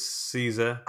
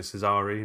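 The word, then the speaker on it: Cesare: Conrad Veidt plays Caesar... I know, I was pronouncing it Cesari in my mind. I don't know about you. – Yeah. I'm happy with either. – Okay. Caesar, it is.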